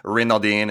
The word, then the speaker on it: Renaudin: I'm at this point in my life where I used to be like super proud of the last name and would try to pronounce it like Renaudin and try to get all fancy with it. But like the deeper you get, the more you just like, just call me Joe Renaudin.